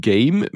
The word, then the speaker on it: G'AIM: Und Game mit Apostroph A-I-M, weil Aim natürlich auch das englische Wort für Zielen ist. Also Game ausgesprochen, aber mit Aim geschrieben.